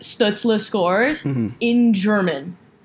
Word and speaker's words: Stützle: What's his actual pronunciation? I want to make sure I get it right. – Stützle. – Okay, Tim Stützle.